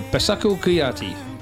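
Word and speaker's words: Bassekou Kouyaté: Baseku Kuyate? – Yes. – Yeah, Baseku Kuyate.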